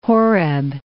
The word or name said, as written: Horeb, Mount